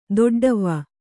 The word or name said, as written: ದೊಡ್ಡವ್ವ